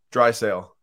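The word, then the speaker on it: Draisaitl: Drysdale. – Drysdale? – Yeah, Leon Drysdale. – No T pronunciation. – Maybe there's a little subtle T, but...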